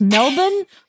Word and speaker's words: Melbourne: Melbourne, Australia is pronounced Melbourne? – Melbourne.